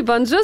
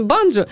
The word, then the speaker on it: банджо: Возьми банджо, сыграй мне на прощание. Правильно говорить, конечно, банджо, но неважно.